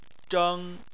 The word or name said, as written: zheng1